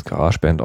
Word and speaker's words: GarageBand: GarageBand oder wie auch immer man manche das aussprechen wollen. Ich spreche es GarageBand aus, das Programm von Apple, was bei jedem Mac somit beigeliefert wird.